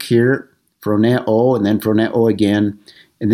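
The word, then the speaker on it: phroneo (φρονέω): I've got it there, phroneo is the way you pronounce that.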